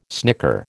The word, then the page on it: snicker: American English